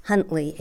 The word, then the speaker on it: Huntley: Jerry, J-E-R-Y, middle initial Y, Huntley, H-U-N-T-L-E-Y.